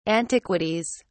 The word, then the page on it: antiquities: British English